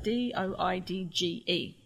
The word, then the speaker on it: Doidge: D O I D G E, Doidge.